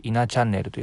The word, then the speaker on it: 17チャンネル: ハッシュタグは数字の 17 にカタカナでチャンネルと書いて稲チャンネルと読みます、